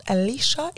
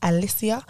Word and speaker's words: Alicia: I have that all the time because my name is not Alicia, it is Alicia.